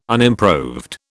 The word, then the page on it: unimproved: British English